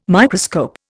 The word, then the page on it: Microscope: Indian